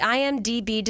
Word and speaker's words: IMDb: The IMDb description.